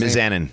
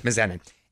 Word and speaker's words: Mizanin: How you pronounce Mizanin. – Mizanin.